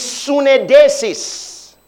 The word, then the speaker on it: suneidesis: So that word conscience is sunedesis. Sunedesis. It says, that word conscience, okay, is sunedesis. I'll spell it for you. S-U-N-E-I-D-E-S-I-S.